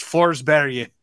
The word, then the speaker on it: Forsberg: Peter Forsberg is not a person during the duration of this tournament. It's Forsberg. – Really? – That's how you say it.